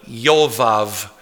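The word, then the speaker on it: Yovav (יובב): It's actually pronounced Eov. And the other one, Yovav.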